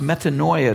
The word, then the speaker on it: metanoia: The Greek word translated as repent is pronounced metanoia.